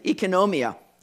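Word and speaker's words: oikonomia (οἰκονομία): That word dispensation is the Greek word oikonomia, or oikonomia. That's how you say it, oikonomia.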